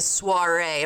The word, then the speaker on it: soiree: And I'm going to say it's a soiree.